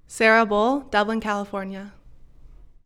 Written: Sarah Bull

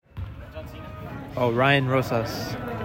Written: Ryan Rosas

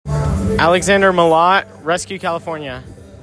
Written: Alex Mallot

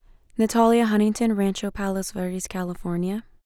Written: Natalia Huntington

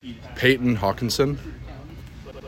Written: Payton Hawkinson

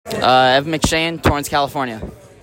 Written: Evan McShane

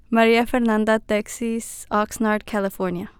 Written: Maria Fernanda Texis

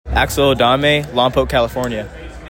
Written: Axel Adame

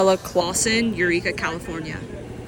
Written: Isabella Clawssen

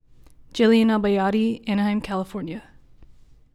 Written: Jillian Albayati

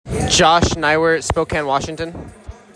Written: Josh Neiwert

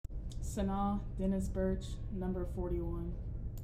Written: Sanaa Dennis-Burch

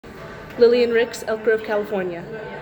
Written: Lilly Rix

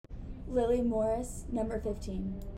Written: Lillian Morris

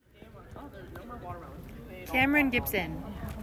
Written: Cameron Gibson

